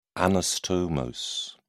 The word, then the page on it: anastomose: British English